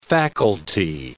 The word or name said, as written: faculty